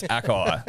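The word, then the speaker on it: açaí: it's actually pronounced Akai.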